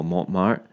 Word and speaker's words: Montmartre: Actually, on my way to, uh, I'm going to totally butcher this because I don't speak French very well, Montmartre. – Oh, that's good.